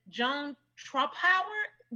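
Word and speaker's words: Joan Trumpauer: And his mom, what tops everything off is that his mother is, he's also the son of the infamous Joan Trumphauer. Did I say her name right? – You did.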